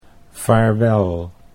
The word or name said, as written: vaarwel